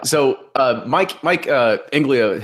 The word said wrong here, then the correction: Inglima: has said, Inglima. I'm really bad at pronouncing names tonight. But anyway. – Inglima. That's the other Superfly guy.